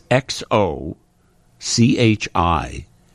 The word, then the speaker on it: Xochimilco: It's X-O... C-H-I-M-I-L-C-O.